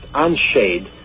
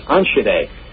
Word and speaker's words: Enschede: to a city that looks like it should be pronounced Enschede, but it's not. It's pronounced Enschede. It's spelled E-N-S-C-H-E-D-E.